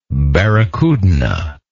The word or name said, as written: barracudina